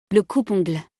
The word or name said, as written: Le coupe-ongles